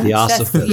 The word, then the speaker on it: theosophists: Yes, and it says it was adapted by 20th century theosophists. Am I butchering that? – Theosophists. – Yes, there we go. – Theosophists. – Yeah. Theosophists. Okay.